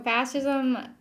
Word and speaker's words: fascism: Like fashion, right. Fashion, but fascism.